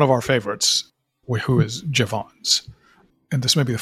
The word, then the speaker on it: Jevons: You will now tell me it's Jevons, and then I'll... – All right. So we'll call him Jevons.